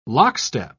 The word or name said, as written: lockstep